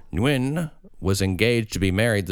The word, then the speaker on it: Nguyen: Nguyen. – Nguyen. It's spelled N-G-U-Y-E-N. Nguyen. – Wow. – And it's like one syllable, but it's like Nguyen.